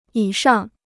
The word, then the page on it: 以上: yǐ shàng